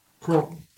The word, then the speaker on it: Croughton: Or is it Croughton? How do you pronounce it? C-R? – I'm lost where you are. Oh, I'm right here. – Croughton. – Croughton. In Oxfordshire. – Like Croughton. – Say it again.